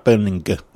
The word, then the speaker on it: Berninger: Matt Benninger. I've done it again. It's like silent G. – It's like Joel's here. It's a hard G. – Hard G. Matt Berninger.